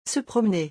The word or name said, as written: se promener